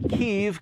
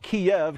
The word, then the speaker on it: Kyiv: I'm told to pronounce it Kyiv because that's how the Ukrainians pronounce it, not Kiev, because that's how the Russians pronounce it.